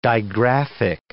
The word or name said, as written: digraphic